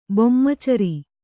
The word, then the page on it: ಬೊಮ್ಮಚರಿ: kannada